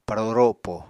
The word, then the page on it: paro ropo: Apatani